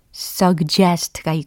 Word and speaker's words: suggest: 어, 미국식 먼저 알려드리면 suggest, suggest, suggest 이렇게 발음이 돼요. 근데 영국식으로 발음을 하면 suggest, suggest, suggest 이렇게 발음이 돼요. 차이가 느껴지시나요? suggest, suggest, suggest, suggest 느껴지셔야 할 텐데 suggest, suggest 네. 그래서 미국식이든 영국식이든 본인에게 편하신 방법을 택하셔서 연습해 두시면 좋겠습니다. 서그 제스트는 아니다라는 거 기억하시고요.